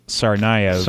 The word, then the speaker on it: Tsarnaev: Are we saying that right? I can't remember. – I've heard it pronounced Tsarnaev. – Tsarnaev, yes. I think that's more like it.